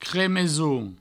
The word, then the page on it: cremeson: cremesoun